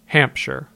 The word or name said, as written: Hampshire